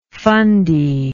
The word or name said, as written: fundy